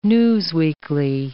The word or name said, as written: newsweekly